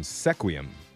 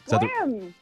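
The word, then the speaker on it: Sequim: And you're going to be playing Nessa from Sequium. – Squim. – Squim. – You can say it right. – Why is there S-E? – That's how you say it.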